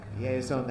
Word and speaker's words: Yehizon: Yeason, that first name is spelled Y-E-H-I-Z-O-N.